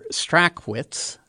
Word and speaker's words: Strachwitz: Mr. Strachwitz, which is how his name is properly pronounced, was 91 when he passed away on Friday and left behind so many people.